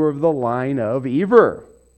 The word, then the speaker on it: Eber: There would be a dot right there if you were to say Eber. So, the pronunciation is Eber.